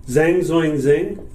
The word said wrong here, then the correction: Zhang Zongchen: Zhang Zongchen. – Okay. – There we go. I got it by the end. Zhang Zongchen. – Zhang Zongchen. – There you go. – We're sorry for pronouncing it wrong.